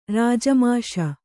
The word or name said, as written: ರಾಜಮಾಷ